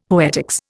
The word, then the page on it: poetics: Indian